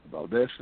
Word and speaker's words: Valdes: And as always, Valdes is spelled with an S, not a Z.